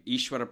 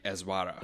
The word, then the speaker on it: Ishwara: People don't mess it up because most people in this country, when they see the spelling of the name, would say Ezwara, which is not the way it's pronounced, right.